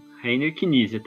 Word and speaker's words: Rainer Knizia: Eu falei certo? É Rainer Knizia? – É assim que eu pronuncio. Rainer Knizia também.